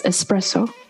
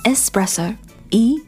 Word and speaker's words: espresso: Yeah, it's espresso. – 嗯，espresso 没有 x 的发音，没有。 – espresso，e s p r e s s o，espresso。